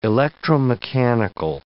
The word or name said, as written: electromechanical